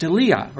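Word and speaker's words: deilia (δειλία): The word timidity or fear is translated from the Greek word deilia, D-E-I-L-I-A, pronounced deilia, or it's really deilia.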